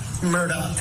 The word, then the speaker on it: Murdaugh: It's very confusing because it's spelled M-U-R-D-A-U-G-H, but apparently it's pronounced Murdaugh.